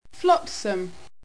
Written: flotsam

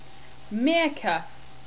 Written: Meerca

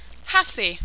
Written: hasee

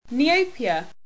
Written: neopia

